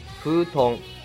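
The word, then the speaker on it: 風遁: 风遁，普通，遁这个字的发音是痛，结合上风、水、雷、火、土五个字各自的读法，就是忍术属性的正确说法。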